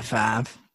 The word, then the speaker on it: Favre: But there's no way you pronounce F-A-V-R-E as Favre. – Right. Doesn't make any sense. – The R sounds comes before the V sound.